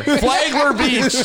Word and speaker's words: Flagler Beach: In Flagleri Beach. – Flagleri Not the pronunciation. – Flagler. Flagler Beach.